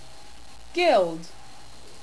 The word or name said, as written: guild